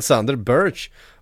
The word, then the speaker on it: Sander Berge: han gick ju själv ut i en video och så här uttalar man sitt na mitt namn. Och så sa han Sander Birch.